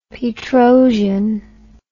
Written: Petrosian

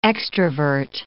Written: extrovert

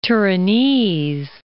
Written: Turinese

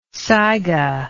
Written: saiga

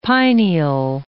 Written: pineal

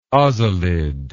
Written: ozalid

